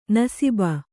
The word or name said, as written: ನಸೀಬು